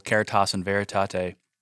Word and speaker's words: Caritas in Veritate: You write in your book, referring to Pope Benedict XVI's encyclical Caritas in Veritate. Hopefully I pronounced that correctly. – Yeah, you got it.